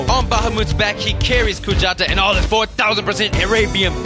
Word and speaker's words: Kujata: So remember, they are pronounced Kujata, Kujata. and Bahamut. Bahamut.